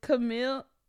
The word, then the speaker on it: Camila: How do you say her last name? Camille Cabello. – Who are you talking about? – You know the girl from Fifth Harmony, and she went solo. It's Camila. Say her name. – I can't. – It's Camila Cabello.